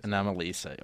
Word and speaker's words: Anomalisa: So the Best Animated were how do you pronounce it? – Anomalisa. – Okay, I was pronouncing it wrong.